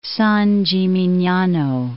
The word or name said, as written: San Gimignano